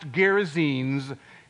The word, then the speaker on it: Gerasenes: She could not pronounce that word, and she tripped over it several times. And then she heard from the bedroom her husband calling out, honey, it's pronounced Gerasenes, keep on reading.